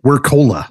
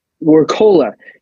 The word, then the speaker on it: Wirkola: Wirkola. – Wirkola.